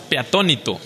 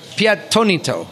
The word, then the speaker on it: Peatonito: Could I say that correctly? I'm a Minnesotan, so that's kind of a hard one for me. – Yeah, the correct way to say it is Peatonito. – Peatonito.